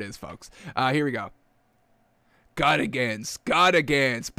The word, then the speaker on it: Gutekunst: I want to say Gutentag, but it's not Gutentag. Packers general manager Brian Gutekunst.